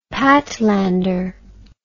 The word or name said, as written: Patlander